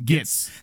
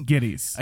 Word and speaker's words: Gittes: And I love how he always says his name wrong. He calls him Gitz. – Mr. Gitz. It's Gittes.